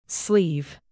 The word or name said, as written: sleeve